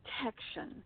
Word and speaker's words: Texan: Texan. I think it's sort of like taken on the chin.